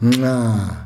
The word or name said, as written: nxaa